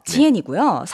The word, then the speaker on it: 健: 발음은요, 지엔이고요. 네. 성조는 탄탄하고 강해야 되니까 몇 성일까요? – 사성이요.